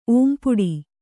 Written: ಓಂಪುಡಿ

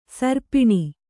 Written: ಸೀರ್ಪನಿ

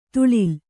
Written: ತುಳಿಲ್

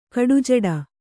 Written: ಕಡುಜಡ

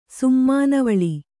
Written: ಸುಮ್ಮಾನವಳಿ

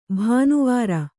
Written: ಬಣ್ಣವೇರು

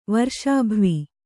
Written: ವರ್ಷಾಭ್ವಿ

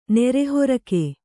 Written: ನೆರೆಹೊರಕೆ

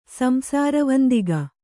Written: ಸಂಸಾರವಂದಿಗ

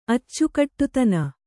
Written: ಅಚ್ಚುಕಟ್ಟುತನ

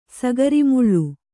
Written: ಸಗರಿಮುಳ್ಳು

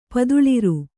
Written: ಪದುಳಿರು

